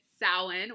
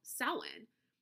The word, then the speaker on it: Samhain: And I always thought Samhain was spelled like S-O-W-E-E-N.